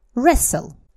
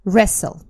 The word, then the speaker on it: wrestle: Wrestle. Wrestle. Это слово, вот вы его сейчас не видите, пишется с буковкой W впереди. W-R. Wrestle.